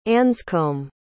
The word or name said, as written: anscomb